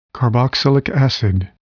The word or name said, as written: carboxylic acid